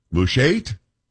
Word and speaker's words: Mushait: I don't know how to say this, M-U-S-H-A-I-T, Mushait? We're going to go with that. Paul, are we good with that? You're not top of that, are we? – Say it the way you need to say it. – Mushait? – Yes. – Yes, that's good. That's good.